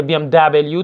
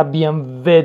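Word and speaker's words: BMW: به طور مثال کلمه بی ام و مربوط به ماشین می بی ام و که خیلی بی ام دبلیو تلفظ میکنن، خب این کلمه بی ام و هستش چون کلمه آلمانی هستش و بی ام و درسته نه بی ام دبلیو.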